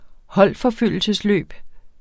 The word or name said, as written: holdforfølgelsesløb